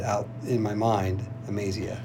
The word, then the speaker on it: Amaziah: How did you say this name? Am- Amazia? Uh, you say it Amaziah. I do, and, but it doesn't matter to me. – Yeah, so it's Amaziah.